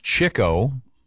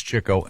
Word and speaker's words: Chico: It's pronounced Chico. Really? – Spelled Chico. – I've never heard that one. – But it's Chico.